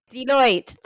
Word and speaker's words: Deloitte: Deloitte. – Deloitte.